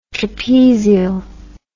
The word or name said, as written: trapezial